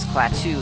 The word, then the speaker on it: Klaatu: My name is Klaatu.